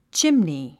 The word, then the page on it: chimney: tʃímni; チムニー